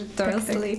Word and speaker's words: Dursley: No tak z brytyjskim, w ogóle angielskim akcentem, powiedzmy, że Dursley.